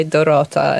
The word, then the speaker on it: Dorota: That I usually go just by Dorota, and that's what's written on my car.